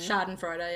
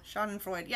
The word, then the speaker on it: Schadenfreude: Schadenfreude? You know what I mean. – Schadenfreude, yeah. – Schadenfreude.